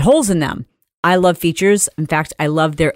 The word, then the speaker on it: Feetures: It is spelled F-E-E-T-U-R-E-S, like feet, Features.